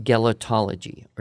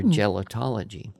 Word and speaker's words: gelotology: And there is a word to describe the science of laughter and relatedly humor, and that is a word from the Greek root gelo or jello, G-E-L-O. So you'd pronounce it gelotology or gelotology.